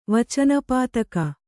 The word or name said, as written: ವಚನಪಾತಕ